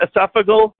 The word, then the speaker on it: esophageal: So esophageal, how do you pronounce that? Esophageal? – Esophageal. – Feces. Esophageal, yep.